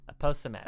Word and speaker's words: aposematic: Aposematic. Is that how you pronounce it or apo? – Aposematic.